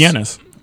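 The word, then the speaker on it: Janus: Well, what do you say? – Yanis? It's Yanis. – Okay. – Y-A-N-I-S. Yanis. – That is not how it's spelled. – I know, but that's how you're supposed to say it, at least.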